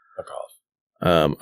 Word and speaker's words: Duckov: It's spelled D-U-C-K-O-V. – Dukov.